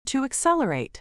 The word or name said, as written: to accelerate